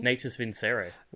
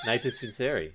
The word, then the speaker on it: Natus Vincere: Um, what was that pronunciation again of Navi? – Of Navi? Navi? Natus Vincere. – Wilco? – Take I don't know. Okay. Nathan Spinseri. – That's there we go.